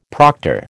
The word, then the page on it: proctor: American English